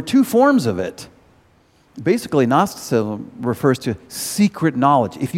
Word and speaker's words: Gnosticism: 1 John explains about Gnosticism, or Gnosticism if you pronounce the G.